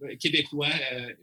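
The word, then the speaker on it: Québécois: Well, in English, I guess it's Quebecers, but Quebecois.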